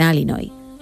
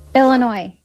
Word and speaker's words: Illinois: Illinois. – Illinois. No Illinois. Bien. Sigue, no sé si lo habéis escuchado, yo sí lo he escuchado. Dice que pronuncia Illinois.